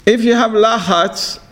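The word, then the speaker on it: Lachatz (לחץ): So you know in Hebrew, stress, how do you say stress in Hebrew? Lachatz. Lachatz means stress. You know Hebrew? No. Lachatz, lamed tzadi het, means stress, problems.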